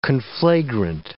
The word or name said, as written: conflagrant